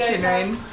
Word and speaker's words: Kinrain: No, I pronounce it Kinrain. It's like Celtic or Celtic.